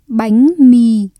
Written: bánh mì

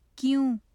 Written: kyon

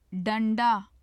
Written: danda

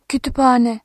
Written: kütüphane

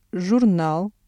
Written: журнал

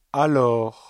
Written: alors

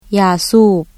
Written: ຢາສູບ

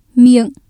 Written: miệng

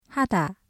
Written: 하다

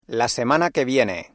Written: la semana que viene

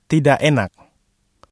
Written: tidak enak

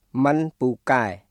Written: មិនពូកែ

